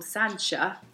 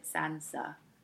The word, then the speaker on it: Sancha: And another Borgia called Joffre, who actually married a chick called Sancha. – Wow. – Yeah, except if you were reading it, you might accidentally pronounce it Sansa. You see, Game of Thrones.